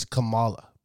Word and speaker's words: Kamala: It's Kamala, but I call her Kamala, nigga. That's how I pronounce it. – You're almost saying caramel.